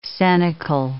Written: cenacle

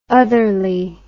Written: otherly

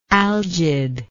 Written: algidness

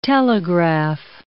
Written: telegraph

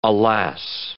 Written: alas

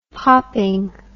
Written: popping

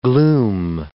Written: gloom

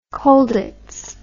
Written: Colditz

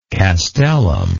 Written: castellum

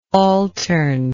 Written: altern

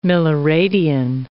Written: milliradian

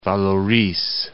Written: Vallauris

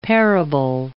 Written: parable

